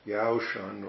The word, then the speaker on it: Yaoshan: So Yaoshan or Yakusan, that's the same name, same person in either in Chinese, Yaoshan, or Yakusan in the Japanese way of pronouncing it.